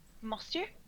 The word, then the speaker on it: Monsieur: Mansour. Am I doing that pretty close to the French pronunciation, or am I way off? – Monsieur. – Monsieur.